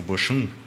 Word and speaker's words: Bochum: Ele tem 30 anos, eu estava vendo, e ele é titular, ele é lateral esquerdo titular do Bohan, o Bochum.